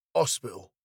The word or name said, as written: hospital